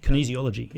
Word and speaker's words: kinesiology: Kinesiology? – Kinesiology.